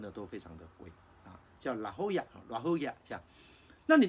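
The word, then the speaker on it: La Jolla: 所以我接待了很多的朋友，那么这些朋友来的时候呢，我一定会跟他们分享一个经验，我会带到圣地亚哥最有名的海边，叫做拉霍亚，那是一个西班牙文的发音，如果你看英文的话，拼音拼出来不是这样，可是因为它是西班牙发音，所以我们大家都知道拉霍亚。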